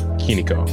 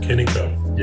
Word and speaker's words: Kinicho: Your company, as I am remembering it, is pronounced as Kinicho. – Kinicho. – Yeah. Kinicho. So Kinicho is all about spatial audio,